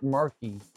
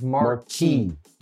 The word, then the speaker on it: marquee: Okay, like, you're not even saying it right. You're saying it like Marky Mark. It's not marquee, it's marquee.